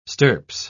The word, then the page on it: stirps: British English